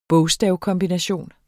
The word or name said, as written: bogstavkombination